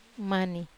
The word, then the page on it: mani: Wamesa